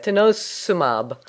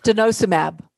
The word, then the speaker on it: denosumab: Denosumab. Denosumab.